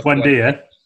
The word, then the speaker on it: Buendia: boys, is it Cantwell, the midfield player beginning with B? Can, can never pronounce his name. – Oh, Buendia. Buendia, there you go.